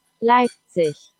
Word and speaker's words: Leipzig: Leipzig.